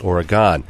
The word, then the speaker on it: Oregon: Unlike the state of Oregon, the end of which is pronounced like begin, the proper noun here, used by Daytonians, is pronounced Oregon, with an ending that rhymes with John.